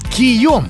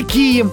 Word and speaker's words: кий: Ха-ха, вооружен-то он был кием или кием. Ударение можно ставить и так, и так.